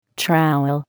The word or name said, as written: trowel